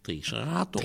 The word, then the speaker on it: Triceratops: Ik vind dat het Triceratops. – Triceratops. – En dat komt omdat de klemtoon in het Nederlands op de tweede lettergreep van achteren valt in het algemeen.